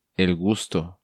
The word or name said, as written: El Gusto